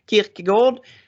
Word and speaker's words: Kierkegaard: Nej, filosofen Søren Kierkegaard. Uttalar jag rätt nu? Gård, Kierkegaard, dansken. Han myntade ett begrepp som kallas för ressentiment.